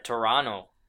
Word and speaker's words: Toronto: I say, I think the locals say Toronto, but like... – Toronto. – Toronto. – No, it's Toronto. That's how you say it.